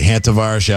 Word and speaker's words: Hantavirus: And it's actually Hantavirus. I guess I was pronouncing that wrong on the show last night.